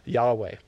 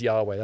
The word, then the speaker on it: Yahweh: He's calling himself Yahweh. The actual letters is YHWH, but the best way to pronounce it is Yahweh.